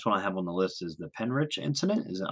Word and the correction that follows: Pentyrch: You say it's pronounced Pen Turk. It's just like pen you write with, and Turk, Pen Turk.